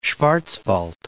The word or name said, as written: Schwarzwald